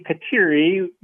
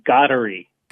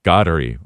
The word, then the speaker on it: Kateri: We say Kateri, but in Mohawk, it would be Gaudery. – Gaudery.